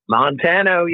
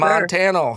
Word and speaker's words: Montano: Montano, yes, sir. – Montano, how are you doing today? – I'm doing great, man.